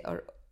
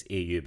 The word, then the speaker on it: Au: If that's how you say it. Or, oh, is it like O? – No, I think it's AU because AU is the chemical symbol for gold.